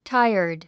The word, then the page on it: tired: táiərd; タイアァド